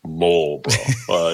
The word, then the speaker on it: Marlborough: I'm sorry, you have to pronounce it Marlborough,